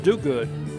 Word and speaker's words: Diuguid: New pitcher in for the Hilltoppers is a left-hander, Lane Dugood. D-I-U-G-U-I-D. Pronounced Dugood.